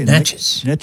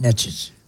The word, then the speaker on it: Natchez: I couldn't believe that the governor mispronounced Natchez this morning. – What do you say? – Natchez. – Yeah, Natchez.